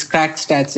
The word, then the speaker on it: Crack Stats: I guess I'm pronouncing it right. It's Crack Stats, if I'm not wrong, or Crack A. – Yeah, that's spot on. It's basically the word English, crack, it sounds the exact same in Portuguese, but it's just spelled differently.